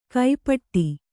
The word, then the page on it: ಕೈಪಟ್ಟಿ: kai paṭṭi